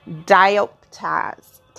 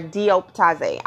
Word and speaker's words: Dioptase: I it's you know people pronounce it differently, and how I see it is probably different than how most people are trying to say it, but it's D I O P T A S E.